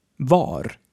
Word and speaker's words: var: This is also true for var, which is a simple past of vara, to be. So both vad and var are pronounced the same, va.